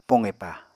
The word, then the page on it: poqepa: Apatani